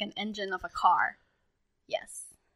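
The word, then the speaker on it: Engene: And then I think their fandom name is Engine. But it's not spelled engine. It's spelled and gene. So like the genes, like the genetics of DNA will like, because they're all brought together kind of thing.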